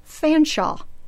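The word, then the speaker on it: Featherstonehaugh: And finally, my very favorite, Featherstone Haw is actually Fanshawe.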